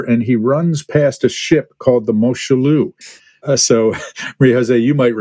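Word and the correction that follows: Moshulu: remember this little tidbit, but any thoughts on the, I'm sorry, the Mushulu, it's pronounced. Tell us about the Mushulu, Maria Jose, and why it's relevant to this podcast.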